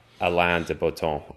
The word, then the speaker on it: Alain de Botton: Alain de Botton. I'll pronounce his name as I believe it's pronounced in French, but I could be wrong.